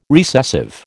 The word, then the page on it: recessive: American English